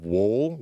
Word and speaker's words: Woll: They brought in Joseph Woll, which is how you now say his name.